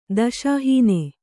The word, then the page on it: ದಶಾಹೀನ: daśa hīna